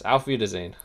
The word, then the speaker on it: Auf Wiedersehen: So maybe we should just say, like you said, Auf Wiedersehen. Did I say that right? – Yes, Auf Wiedersehen.